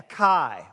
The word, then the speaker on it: chi: It's pronounced with a K. A chi.